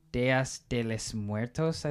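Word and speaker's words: Días de los Muertos: The animation of the Dias de Muertos. That's how you say it, right? – You mean the Day of the Dead? – Yeah. – Dias de los Muertos, I think is how you say it. – There it is. Thank you. But yeah, I think the way that it all comes together and just the characters are all really likable.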